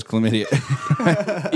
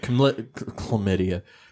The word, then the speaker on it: chlamydia: It has chlamydia. – Yeah, this thing has like chlamydia. Chlamydia, said it right the second time.